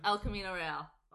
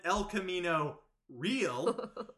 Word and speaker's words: El Camino Real: Which is just offends me in so many ways, because we either need to commit to the Anglo version and call it El Camino Real, or pronounce it correctly and say El Camino Real, because you can't go halfway.